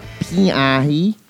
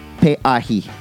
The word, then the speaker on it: Pe'ahi: It's not Pe'ahi, it's Pe'ahi. And I will never say that wrong again.